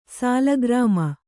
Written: ಶಾಲಗ್ರಾಮ

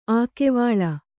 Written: ಅಕ್ಕಿವುಲ್ಲು

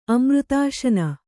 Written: ಅಮೃತಾಶನ

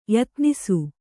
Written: ಯತ್ನೈಸು

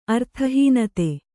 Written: ಅರ್ಥಹೀನತೆ

